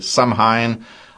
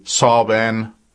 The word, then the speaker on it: Samhain: some say Samhain, others say Samhain, Samhain. You spell it S-A-M-H-A-I-N.